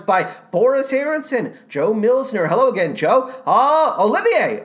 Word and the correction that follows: Oliver: Oliver? No. Yes, Oliver Smith. Not Olivier Smith.